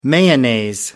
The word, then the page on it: mayonnaise: American English